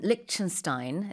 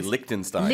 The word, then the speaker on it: Liechtenstein: In English, we say Liechtenstein. – Liechtenstein. – But okay. – I think the way you're saying it is the closer to the German pronunciation.